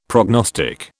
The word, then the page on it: prognostic: British English